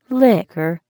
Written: liquor

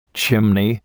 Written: chimney

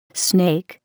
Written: snake

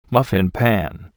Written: muffin pan

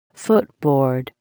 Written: footboard

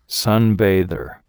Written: sunbather

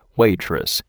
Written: waitress

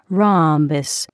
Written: rhombus

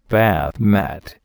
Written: bath mat

